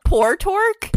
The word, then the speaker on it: Portork: Portork? – Portork. – How do you spell it? – P-O-R-T-O-R-K.